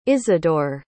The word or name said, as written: isidor